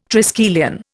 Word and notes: triskelion: Indian

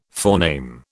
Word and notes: forename: British English

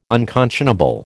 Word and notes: unconscionable: American English

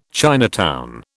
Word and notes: Chinatown: British English

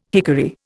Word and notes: hickory: Indian

